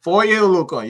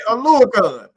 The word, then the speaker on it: Oluokun: Again, don't ask me to say his name, Foye or Louis Kahn. – Foye Oluokun, you got it right. – Oh, let's go! – You got it right. Oluokun.